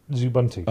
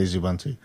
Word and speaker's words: Xubuntu: Yeah, I'd say Zubuntu. But is that laziness? It's like, I think it's partly laziness that I say Zubuntu, because it's easier, rather than, because I'm saving myself one syllable. If I say Xubuntu, it's longer than saying Zubuntu.